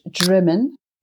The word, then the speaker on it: Drymen: Yeah, it's pronounced Drymen.